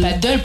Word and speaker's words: paddle: C'est juste que c'est pas du tout la même prononciation. – Est-ce qu'on peut juste dire paddle? – C'est paddle pour le tennis. – Et paddle. – Et paddle pour la planche.